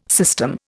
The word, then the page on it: system: Indian